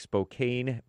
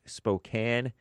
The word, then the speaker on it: Spokane: It looks like Spokane, but it's pronounced Spokane, Washington State.